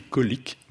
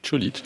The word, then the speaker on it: Čolić: Velibor Čolić, c'est comme ça qu'on dit. Ça s'écrit colique, mais c'est Čolić.